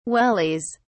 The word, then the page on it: wellies: British English